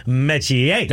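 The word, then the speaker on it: Metchie: But there is a bio out there that explains that it is actually pronounced Mechie.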